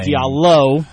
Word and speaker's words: Diallo: I think that's one reason it's going to be pivotal for Hamadou Diallo, however I say his name. – Diallo. You were very close.